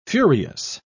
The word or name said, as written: furious